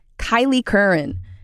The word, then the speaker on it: Kylie Curran: We said it wrong earlier. It's Kylie Curran.